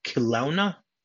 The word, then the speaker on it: Kelowna: They took off from a place called Kelowna. – Kelowna. – It's spelled differently than it's pronounced, I guess.